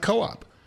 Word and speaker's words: KOOP: We pronounce it co-op, because that's how you pronounce those letters.